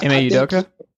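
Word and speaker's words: Ime Udoka: And I think, you know, I don't want to butcher the new coach's name, but M.A. Udoka.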